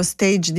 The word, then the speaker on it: stage: Poi arrivano gli stage invece quando inizia a lavorare, sono i periodi di tirocinio, di formazione, quindi va pronunciato alla francese.